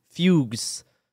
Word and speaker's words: fugue: In some cases, terrifying visions were followed by fugus, which is an old term for fugues. It says fugues. – Fugue. It's pronounced fugue.